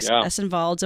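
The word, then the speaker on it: Ešenvalds: Arvo Pärt, the world's most performed living classical composer, and Erik Essenvalds.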